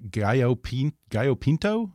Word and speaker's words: Gallo Pinto: Yeah, I think you say that they have the healthiest breakfast in the world, something called, I hope I have this pronounced right, Gallo Pinto? – That's right.